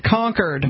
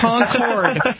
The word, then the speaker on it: Concord: You've got to pronounce it Concord. – Yeah, Concord. – Concord. It is not Concord. It is not the plane. It is like the word conquered. You conquered someone in battle. That is how you pronounce it when you get here.